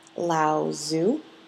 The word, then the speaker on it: Lao Tzu: There's a quote, and I know that I'm going to butcher this man's name, but I think it's pronounced Lao Tzu, but just in case, it's spelled L-A-O, and then his last name is T, Z as in zebra, U.